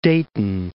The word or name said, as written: Dayton